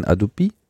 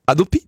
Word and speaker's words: Hadopi: Wenn du das richtig französisch aussprechen willst, musst du natürlich sagen Adopi. Adopi?